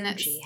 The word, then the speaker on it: Googie: And Guji. How do you spell that? – G-O-O-G-I-E. – Okay. – It's a weird word. Sounds kind of like bougie.